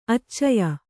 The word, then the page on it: ಆಚಯ: ācaya